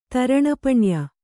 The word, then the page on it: ತರಣಪಣ್ಯ: Kannada